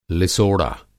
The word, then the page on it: लिसोड़ा: Hindi